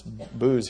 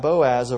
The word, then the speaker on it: Boaz: It's Booz here, is the way that it's spelled, but we know it's Boaz of Rachab. A little bit different spellings, but begat Boaz of Rachab, and Boaz begat Obed of Ruth, and Obed begat Jesse.